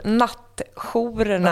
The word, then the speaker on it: nattjourerna: Jo, nej, ja, mitt uttal, nattjourerna.